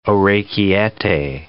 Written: orecchiette

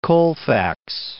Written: Colfax

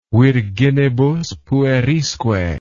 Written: virginibus puerisque

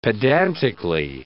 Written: pedantically

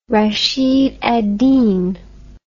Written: Rashid al-Din

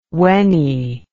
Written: wenny

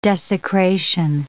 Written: desecration

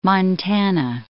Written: Montana

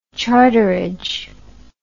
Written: charterage

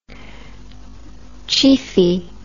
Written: chiefy

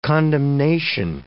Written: condemnation

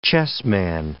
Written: chessman

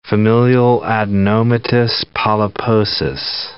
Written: familial adenomatous polyposis

